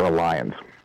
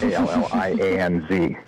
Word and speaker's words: Allianz: I use a company called Allianz, or Alliance, how it's pronounced is a big battle, but it's Allianz.